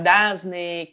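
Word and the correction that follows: DAZN: que é assim que se pronuncia, d a, é DASNO, enfim, já ouvi várias formas, agora eu esqueci como que era a forma é, que é, pronuncia. DASON. Chama. – DASON, é isso, DASON, exato, DASON.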